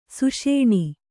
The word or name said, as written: ಸುಷೇಣಿ